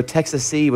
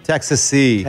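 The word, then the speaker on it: Texassee: It's like Texas plus S-E-E. The name was derived from the fact that all five members were natives of Texas and met in Nashville. – Okay. – So Texassy would be how to say it then. – Texassy. – Texassy. – Like Tennessee.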